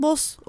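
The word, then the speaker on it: Bosch: O zaman Boş dediğimizde Boss okunuyormuş. – Aa. – Hatta Hieronymus Boss olarak okunuyormuş ama bunu da burada söylemeden geçmek istemedim.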